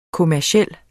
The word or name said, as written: kommerciel